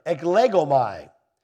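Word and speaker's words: eklegomai (ἐκλέγομαι): The word chosen is, let me say it right, eklegomai. Eklegomai. No, not my Legos.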